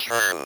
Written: turn

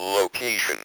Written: location

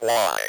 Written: why

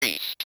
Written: priest

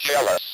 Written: jealous

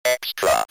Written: extra

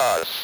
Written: us

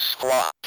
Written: squat

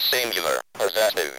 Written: singular possessive

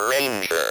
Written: ranger

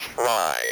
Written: try

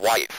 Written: wife

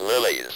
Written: lilies

